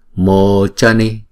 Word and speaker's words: Mochani: Chữ C chúng ta đọc là chờ, và chữ O đọc là hai nhịp. Mochani Mokshani.